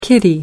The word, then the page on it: kitty: American English